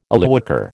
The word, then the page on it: Illywhacker: American English